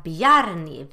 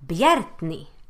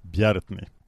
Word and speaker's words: Bjarni: Vi ska inte säga Bjarni, vi ska säga Bjärtni. – Bjärtni? Mm. Okej, Bjärtni.